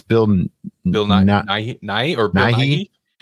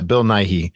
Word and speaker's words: Bill Nighy: Bill Nighy. Yeah. Bill Nighy.